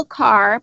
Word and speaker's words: Kear: It's called Fix It, Friends, and it's by Nicole Carr, but it's spelled K-E-A-R.